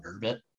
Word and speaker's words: Urbit: Are you familiar at all with Urbit? – Urbit. U-R-B-I-T. Is that how it's spelled? – U-R-B-I-T. So I don't know if you know Moldbug.